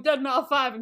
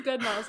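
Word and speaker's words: Deadmau5: It's literally Dead Mouse. – Oh. – Wow. I was like walking around going Dead Ma 5 and people were like, you know that's not how you say it, right? I did not know. Okay. – It's Dead Mouse. It's the one with like the big mouse head.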